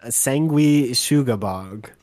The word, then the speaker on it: Sanguisugabogg: Sanguisugabogg is the pronunciation.